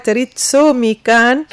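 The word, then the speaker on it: Ritsumeikan: نستسمحك نذكر الاسم ان شاء الله ننطقه بالصحيح، جامعة ريتسومي كان آسيا والمحيط الهادي، هكا اسمها ريتسومي.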